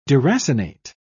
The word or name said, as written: deracinate